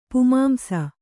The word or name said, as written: ಪುಮಾಂಸ